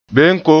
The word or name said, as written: benko